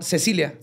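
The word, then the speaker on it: Cäcilia: Andreas nació en Austria y posteriormente se trasladó a Alemania, donde se estableció y contrajo matrimonio con Cecilia. Se escribe Casilia con dos puntitos en la A, pero se pronuncia Cecilia.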